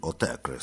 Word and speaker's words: Autechre: Después de Frank Zappa, vamos a seguir aquí con Raras Músicas con Autechre, que se pronuncia así, Autechre, según sus propios creadores, Rob Brown y Sean Booth.